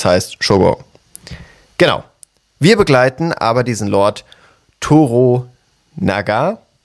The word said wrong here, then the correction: Toranaga: Toranaga, ja. – Toranaga, genau. So ist es ausgesprochen, richtig.